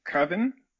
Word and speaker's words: coven: But I'd like to know from the North American in the house, how do you pronounce the word C-O-V-E-N? – Coven? – Yeah. Well, Mando says Coven. – Interesting. Maybe it's a Star Wars universe thing. – So do governors Govan? I don't know. I was curious as to why he pronounced it so strangely.